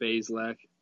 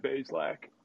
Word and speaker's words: Bazelak: He's Basilic. What's it? How do you pronounce it? – Bazelak. – Yeah, Bazelak.